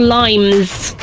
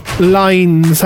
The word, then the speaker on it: Lynes: Did you say Lines or Limes? – Lines, as in like a line. – Right, okay. – But it's spelled L-Y-N-E-S.